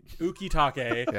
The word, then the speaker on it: Ukitake: Ukitake. Is it Ukitake? – Yeah.